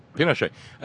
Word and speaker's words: Pinochet: Pinochet.